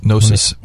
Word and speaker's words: gnosis: Yeah, Gnosis.